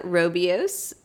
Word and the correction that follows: Rooibos: Rooibos. – Rooibos. – I think, actually, I could be telling you that wrong. – I don't know how to say that. Vanilla something. It's spelled Rooibos. – I think it's rooibos.